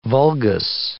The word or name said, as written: vulgus